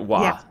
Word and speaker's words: Wa: So obviously, and you've turned this into a book now, and your book is called Wa, and that's how you pronounce it, isn't it? Wa. – Yeah. – I guess there's only two letters, W and A, so there's no real way I can mispronounce that.